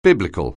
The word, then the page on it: bible: anglais